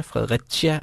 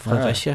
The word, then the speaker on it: Fredericia: Eller, og så er der også, vi er den by, der har tre udtaler. Fredericia og Fredericia.